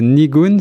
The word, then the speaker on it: Nigun: Alors on va parler euh, de votre tout dernier album qui vient de sortir et qui s'intitule Nigun, si je prononce bien.